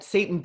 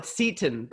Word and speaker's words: seitan: I sometimes say seitan, but it's seitan. And she said she's had the work of the devil. – I know. I wish they would call it seitan, but it's actually pronounced seitan. So seitan. You have to.